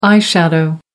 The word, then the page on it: eye shadow: American English